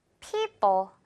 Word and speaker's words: people: 두 번째 단어도 people 이렇게 둘 다 같은 소리 말고요. people. 뒷부분은 가볍게 된 소리입니다.